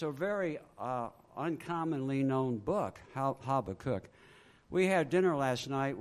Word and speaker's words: Habakkuk: Habakkuk? He said Habakkuk is okay.